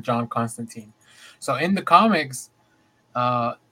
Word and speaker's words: Constantine: Now, the one thing I want to say is I've read tweets that it is Constantine. – Oh, yeah, that's how they say it.